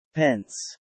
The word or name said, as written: Pence